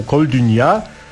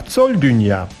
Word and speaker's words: Coldunia: A tutaj ten azerski zespół Koldunia, właśnie źle to wymawiam, bo pierwsza część to twa twa z angolskiego. Tak, Coldunia powinno się wymawiać. Cold to przemilczę, a dunia to świat.